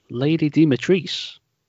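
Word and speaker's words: Lady Dimitrescu: Well, there's one takeaway I took away from the Resident Evil showcase, and that was it's pronounced Lady Dimitrice.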